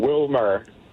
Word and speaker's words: Wielmer: Wilmer. – How do people mess up Wilmer? – Because it's spelled W-I-E-L-M-E-R. They say Wilmer.